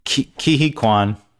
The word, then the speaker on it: Ke Huy Quan: Am I saying it right? Ke Huy Quan? – Ke Huy Quan, yes.